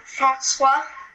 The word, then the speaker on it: François: Francois.